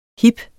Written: hip

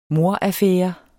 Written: mordaffære

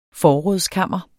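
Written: FORRÅDSKAMRENES

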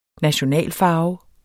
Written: nationalfarve

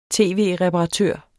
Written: tv-reparatør

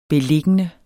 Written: beliggende

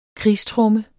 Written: krigstromme

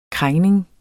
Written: krængning

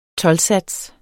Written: TOLDSATSEN

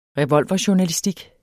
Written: revolverjournalistik

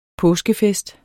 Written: påskefest

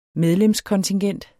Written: medlemskontingent

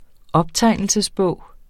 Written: optegnelsesbog

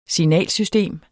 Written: signalsystem

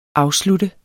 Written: afslutter

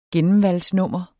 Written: gennemvalgsnummer

